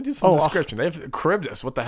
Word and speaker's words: Charybdis: Charybdis, but you can say it like that. – I think it is a Charybdis. I think that's how you say it.